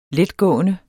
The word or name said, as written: letgående